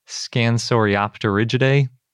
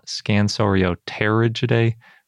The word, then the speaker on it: Scansoriopterygidae: And it's all about that group Scansoriopterygidae, which I always want to call Scansorioterygidae, but apparently it's op, because the P is silent in pterosaur, but then you pronounce it when it's in the middle of a word.